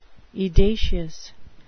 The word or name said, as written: edacious